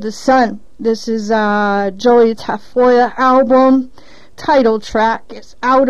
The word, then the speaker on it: Tafolla: It's spelled actually T-A-F-O-L-L-A, but it's pronounced Tafoya.